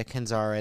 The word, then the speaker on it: Kenzari: Yeah, Kenzari.